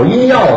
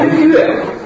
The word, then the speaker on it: 音乐: 讲相声之前，人家是搞音药的。 – 什么音药？ – 搞音乐，搞音乐的。